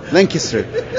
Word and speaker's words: Lancaster: Lancaster. So I have to say it right, otherwise I get a lot of stuff back.